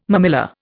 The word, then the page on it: mammilla: Indian